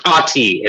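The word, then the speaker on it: Athie: And he is played by, and I'm probably saying this wrong, but Mamadou Afi. – Mm-hmm. Ati, it's silent H.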